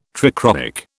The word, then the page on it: trichroic: British English